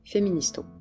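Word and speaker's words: フェミニスト: Pour parler de ce concept étrange et exotique, on le prononce avec des katakana, un système syllabaire employé surtout pour les idées externes au Japon. On dit « féministo ».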